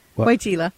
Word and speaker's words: Weigela: is Weigela? – Weigela.